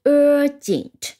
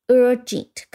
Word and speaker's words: urgent: urgent 一定要注意它的读音，呃，在这里 g 不是读成 g，而是读成 j。那么和它和 e n t 放在一起读成 gent。那前面的 u r 是发成 er，所以是 urgent。那么重音是在第一音节的 urgent，跟我一起拼一下 u r。